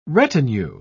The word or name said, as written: retinue